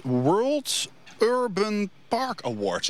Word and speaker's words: World Urban Park Award: Het gaat om het World Urban Park Award. Zeg ik dat zo goed? – Ja, dat is helemaal goed uitgesproken.